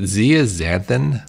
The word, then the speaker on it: zeaxanthin: Is it zeaxanthin? – Yeah, that's good.